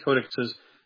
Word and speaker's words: codices: I think they found another parts of another one four, but there's three almost complete codices. That's how you say it in plural.